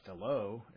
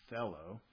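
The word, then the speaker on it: thello (θέλω): Okay, and the accent goes on the first word, and it's not thelo, it's thello.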